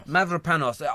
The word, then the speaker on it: Mavropanos: Mavropanos.